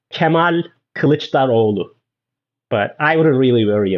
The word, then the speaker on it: Kemal Kılıçdaroğlu: So his full name is Kemal Kılıçdaroğlu.